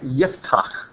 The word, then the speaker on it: Yiftach: And so you see, like Yiftach, which is how his name we pronounce in Hebrew, he opens.